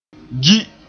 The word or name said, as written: gi